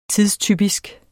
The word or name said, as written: TIDSTYPISK